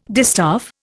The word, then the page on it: distaff: Indian